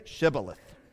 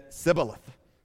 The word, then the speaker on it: Shibboleth: Say Shibboleth." And he said, "Sibboleth," for he could not pronounce it right.